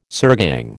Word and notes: sirgang: American English

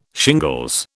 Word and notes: shingles: British English

